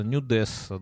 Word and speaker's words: нюдесса: Я говорю, что все э, женские эти самые э, директриса, стюардесса, нюдесса, да, на последний слог падает ударение.